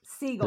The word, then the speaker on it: Segel: You could Segel. – Did I pronounce it wrong? Jason Segel. I was thinking the other.